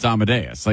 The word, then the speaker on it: Amadeus: Because if you've ever heard it, you know it's Amadeus.